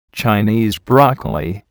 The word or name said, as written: Chinese broccoli